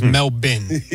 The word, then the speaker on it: Melbourne: It was Melbourne when we started today, but it's Melbourne, okay. And that is courtesy of Noah Eagle. – Yeah, so Melbourne. The Australian pronunciation, is Melbourne.